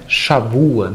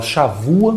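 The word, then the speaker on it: Shavua (שבוע): Semana é Shavua, né? Aí você pronuncia o B como se fosse U, mas é a mesma letra. Só muda a pronúncia, mas é a mesma letra. Né? Eu falo Shavua, mas na verdade eu escrevo Shabua.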